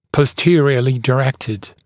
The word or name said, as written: posteriorly directed